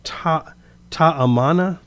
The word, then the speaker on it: Ta'amana: Is it Ta'amana? – That's how I pronounced it, yes.